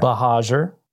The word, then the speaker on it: Buhagiar: Buhagiar.